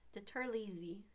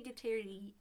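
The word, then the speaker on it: DiTerlizzi: I don't know how to say it. – Tony Deterlizzi. – Yeah, something like Tony Deterlizzi. Tony Deterlizzi. – Sure, yeah. – Deterlizzi.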